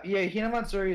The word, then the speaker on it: Hinamatsuri: What is it? Hinamatsuri? Is that how it's pronounced? – Oh yeah, Hinamatsuri.